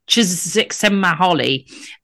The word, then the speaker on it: Csikszentmihalyi: So some people say Csikszentmihalyi.